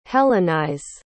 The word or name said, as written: hellenize